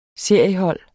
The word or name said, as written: seriehold